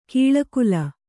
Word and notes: ಕೌಳುಗೋಲ್: kauḷugōl

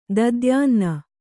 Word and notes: ದಧ್ಯನ್ನ: Kannada

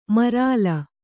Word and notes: ಮರಲ್: maral

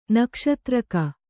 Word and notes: ನಕ್ಷತ್ರಿಕ: nakṣatrika

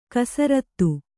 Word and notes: ಕುಸೃತಿ: kusřti

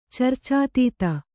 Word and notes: ಚರ್ಚಾತೀತೆ: carcātīte

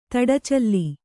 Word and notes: ತಡಚಲ್ಲಿ: Kannada

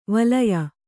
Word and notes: ವಲಯ: valaya

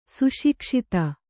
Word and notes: ಸುಶಿಕ್ಷಿತ: suṣikṣita

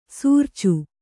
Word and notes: ಸಾರ್ಚು: sārcu

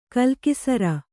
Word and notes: ಕಲಾಕುಸುರಿ: kannada; kalākusuri

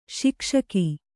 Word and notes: ಶಿಕ್ಷಕ: śikṣaka

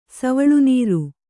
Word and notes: ಸವುಳುನೀರು: savuḷu nīru

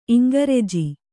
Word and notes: ಇಂಗ್ರೆಜ: iŋgreja

